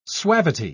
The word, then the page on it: suavity: British English